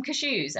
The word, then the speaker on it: cashew: Or like, oh, I feel like munching on some cashews. And my friends would be like, what the fuck are you talking about? And I obviously realised that it was cashew. Yes. Emphasis on the first syllable.